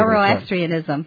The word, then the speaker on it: Zoroastrianism: Zoroastrianism.